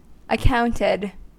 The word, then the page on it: accounted: American English